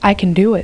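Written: I can do it

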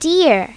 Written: deer

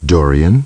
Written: Dorian

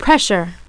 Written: pressure

